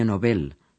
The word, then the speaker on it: Nobel: Varios de ellos fueron distinguidos con el Premio Nobel con acento en la E, que es lo correcto.